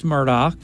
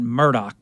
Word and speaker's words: Murdaugh: And I think they pronounce that Murdaugh, actually, right? Not Murdaugh, Murdaugh.